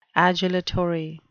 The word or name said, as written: adulatory